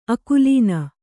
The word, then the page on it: ಆಕಲನೆ: ākalane